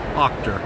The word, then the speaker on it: Auchter: Did I say your last name right? – Well, Auchter. – Auchter, sorry. Butchered that.